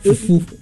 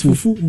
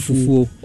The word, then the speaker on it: Fufu: Yeah, how do we pronounce it? – Fufu. It's Fufu. – But sometimes some people call it Fufu.